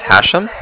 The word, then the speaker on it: Hashem: My name is Hashem. That's H-A-S-H-E-M, as in mother at the end.